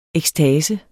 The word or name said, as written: EKSTASENS